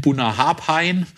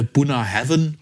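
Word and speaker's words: Bunnahabhain: Buna Heaven, auch was der Unkundige eben immer dann eben, wie es die Unkundigen nennen, Buna Harpain. Aber tatsächlich Aussprache Buna Heaven.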